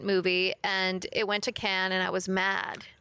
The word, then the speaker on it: Cannes: But the one prior to this went to Cannes. Wow. I mean, to Cannes, sorry, is how you properly say.